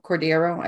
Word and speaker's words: Cordero: Um, Eric Cordero, Cordero. I never. I feel like I'm always butchering his name. Yeah, I never uh, correct you, so I'm just. no, it's right. It's right.